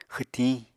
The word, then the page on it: xwtiñ: Apatani